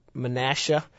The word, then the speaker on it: Menasha: And we decided from that point, okay, let's take some time and come back up to Menasha. – Okay. – I didn't know how to pronounce that originally. – You got it right.